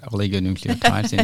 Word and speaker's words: oligonucleotides: You've definitely, gave your best shot. Oligonucleotides indeed is the best pronunciation.